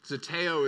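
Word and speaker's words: Zeteo (ζητέω): Zeteo is how you say it.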